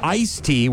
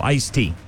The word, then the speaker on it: iced tea: But it's apparently actually supposed to be with the D. – Okay. – Iced tea without the D has become more common in speaking because English speakers regularly leave out sounds and everyday words out of laziness. So iced tea as opposed to iced tea.